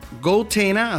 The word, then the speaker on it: goTenna: Bueno, hay un equipo que se llama GoTena. GoTena, así se escribe, GoTenna, que permite crear una especie de network fuera del grid.